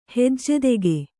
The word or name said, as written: ಹಜ್ಜೆದೆಗೆ